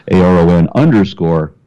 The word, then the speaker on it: Aron: And my channel, of course, on YouTube is A-R-O-N-R-A, right?